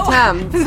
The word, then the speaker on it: Thames: The Thames.